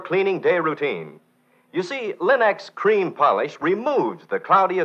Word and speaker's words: Linx: Remember, Linex is spelled L-I-N-X, Linex.